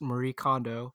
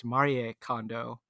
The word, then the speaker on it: Marie Kondo: But I did notice some people calling out that the how you would actually pronounce her name is Marie Kondo. It's a little bit different, but you know, we just want to be respectful toward that.